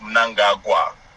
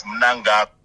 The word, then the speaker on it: Mnangagwa: At Bongles, his surname is pronounced Mnangagwa. So it's Mnangagwa.